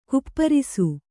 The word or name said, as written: ಗೋಪರಸ